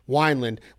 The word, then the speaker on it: Wineland: You have Eddie, do you pronounce it Wineland or Winland? – Wineland.